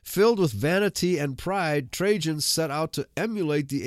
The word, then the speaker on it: Trajan: The year after the death of Mithridates, the Roman Emperor Trajan, and I've been pronouncing it wrong, I've been pronouncing it Trajan, it's Trajan, broke the peace and began military hostilities.